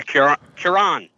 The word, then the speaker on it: Cuarón: Do you know how to say his last name? – Yeah, Cuarón.